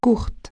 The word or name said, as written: courtes